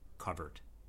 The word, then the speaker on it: covert: In fact, the traditional and long-standing pronunciation of this word is covert, with the same vowel that's in our word cover.